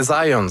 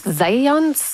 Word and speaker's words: Zajonc: Ben, j'avais dit plus Zajonc, mais... – Ah, parce que j'ai prononcé un petit peu à l'anglais. J'ai prononcé mon polonais à l'anglais.